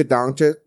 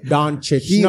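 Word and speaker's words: Dončić: Doncic. No, say the man's name right. We're going to talk about it. Doncic. – Doncic. There you go.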